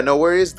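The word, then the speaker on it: NxWorries: NX Worries. – Oh, yeah. No Worries.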